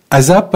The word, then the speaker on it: ASAP: qui est la dirigeante de ASAP, hein, on prononce ça comme ça, spécialiste de l'emballage, de la reliure et du stockage de produits.